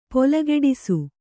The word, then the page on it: ಪೊಲೆಗಿಡಿಸು: polegiḍisu